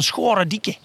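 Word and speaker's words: Scharendijke: Ja, ik heb me heel erg zitten richten op de zee. We zijn in Zeeland. Op de Zeeuwse uitspraak. En dat is iets van Schorendieke.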